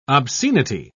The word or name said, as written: obscenity